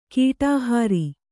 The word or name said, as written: ಕಡೆಹರಿ